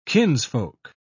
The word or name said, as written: kinsfolk